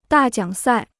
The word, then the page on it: 大奖赛: dà jiǎng sài